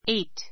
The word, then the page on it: eight: éit; エイト